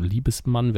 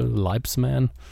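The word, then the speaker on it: Liebesman: Äh, wie spricht man das aus im Englischen? Liebesmann wäre das Deutsche. Leibsman.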